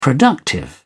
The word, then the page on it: productive: British English